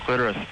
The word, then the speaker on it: clitoris: Listen, when you look at a dictionary, sometimes it will have a couple of pronunciations listed. – Yes. – The first is always preferred. – Oh, interesting. – Nice. – And in the New World, Webster's New World Dictionary. – Clitoris is the first one. – Clitoris is the first, but here's the thing that Kathy might be interested in, because she mentioned it earlier. – And I have one. – The secondary pronunciation is clitoris.